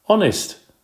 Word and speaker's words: honest: Honest, said Seth, jumping up out of his seat. Sit still, Seth. That's a good guess, but honest starts with a silent H.